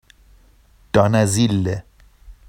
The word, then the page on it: dɑnazille: Vafsi